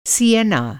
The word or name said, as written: सियेना